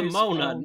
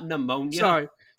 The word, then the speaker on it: Nimona: I really liked Nimona. I know we mentioned that as a surprise. – Nimona, not Nimona.